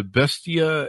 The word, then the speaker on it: Bestia: You talked to Bestia and Bevel. Is that pronounced correctly? – Yeah. So those are two of the best restaurants in Los Angeles.